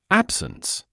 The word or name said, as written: absence